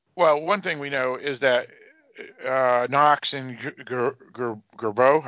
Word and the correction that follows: Joubert: how do you say it, Barrett? – Joubert.